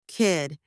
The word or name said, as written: kid